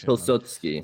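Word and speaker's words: Piłsudski: So I was going to ask you, what is the relevance of Pilsudski? I think I said that right. My Polish pronunciation. – Yes, Pilsudski.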